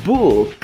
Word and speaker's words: book: Ó, não vai confundir a pronúncia de buck que é o dólar, com book, que é o livro. Buck, book.